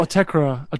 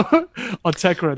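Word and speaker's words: Autechre: I've actually never known how you pronounce that. – I think it's Autechra. I've heard Tom say it. But again, we know Tom is not great with the pronunciation. So Autechra, that's I've heard him say.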